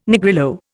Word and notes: Negrillo: Indian

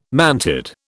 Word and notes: mantid: British English

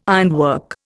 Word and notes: ironwork: Indian

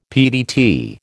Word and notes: PDT: American English

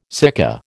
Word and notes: sika: American English

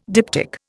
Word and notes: diptych: Indian

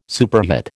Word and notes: superhet: American English

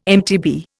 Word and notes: MTB: Indian

